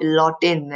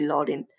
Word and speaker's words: Lottin: Kylian Mbappe Lottin, L-O-T-T-I-N,